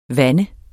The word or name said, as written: vand